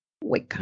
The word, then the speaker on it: Weka: Hueca, ¿cómo es? ¿Cómo se escribe? – Eh, W-E-K-A. – Hueca.